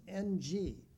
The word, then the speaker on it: Ng: His surname is a diphthong, just N-G in Chinese, and you pronounce that Ng, David Ng.